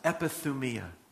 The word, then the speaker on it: epithumia: It's called, it's pronounced epithumia. Epi being upon in the first part of the word, and then thumia is desire.